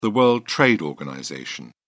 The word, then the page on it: world trade organization: British English